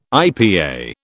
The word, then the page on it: IPA: British English